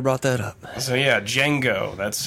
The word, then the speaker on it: Django: Is it pronounced ago. Django or Django? – The D is silent. – If you mm. watch the film, gotcha. Jamie Foxx states in the film, and he at one point says to a, a guy after he shoots him in the nuts, or right before maybe, he says, uh, "The D is silent, hillbilly."